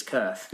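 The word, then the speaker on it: Cirth: Is it is it the Kurth? You're asking the wrong guy. I think so. I know it's spelled C I R T H. I think it's Kerth.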